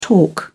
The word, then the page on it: torque: British English